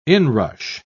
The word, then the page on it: inrush: British English